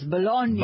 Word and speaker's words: Bologna: Yes. – Bologna? – Yes. – Yeah. All right, I mean the bologna that you eat. 800. – No, bologna that you eat. Bologna is a city. – I know, but bologna, I've seen it spelled the same way. – Yeah, but it's pronounced differently.